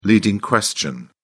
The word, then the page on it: leading question: British English